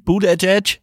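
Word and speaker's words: Buttigieg: Ähm, ja, der Pete, Mayor Pete Boot, Edge, Edge. Ähm, so spricht man aus.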